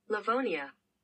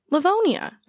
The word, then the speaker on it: Livonia: Livonia. – Livonia.